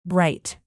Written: bright

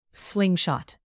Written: slingshot

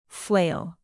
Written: flail

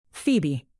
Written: phoebe

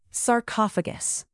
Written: sarcophagus

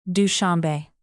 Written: dushanbe

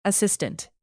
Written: assistant

